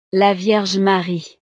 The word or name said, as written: la vierge marie